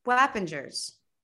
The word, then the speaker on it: Wappingers: Wappingers. – Wappingers Falls. – Yeah, Wappingers. – It's only three syllables.